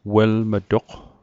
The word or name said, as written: welm'toq